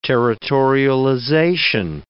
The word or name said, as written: territorialization